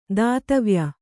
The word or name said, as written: ದಾತವ್ಯ